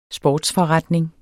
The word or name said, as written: sportsforretning